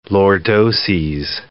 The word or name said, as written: lordoses